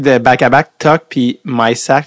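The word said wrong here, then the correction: Mysak: Fait que le monde disait Tuck, MySac. Parce que c'était Yann, Yann Michak, mais finalement, ça se prononce Michak.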